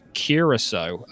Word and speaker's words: Curaçao: Regarding Curaçao, if I remember correctly, it's pronounced Curaçao.